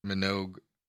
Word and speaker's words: Minogue: Minogue. It has a hard G.